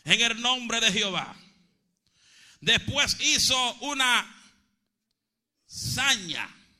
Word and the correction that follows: zanja: si lo dije bien, zanja, amén, zanja, zanja, zanja.